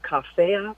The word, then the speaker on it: Coffea: So one of the remedies for insomnia is a remedy called Coffea cruda. So it's spelled like coffee, C-O-F-F-E-A, and then the word cruda, C-R-U-D-A.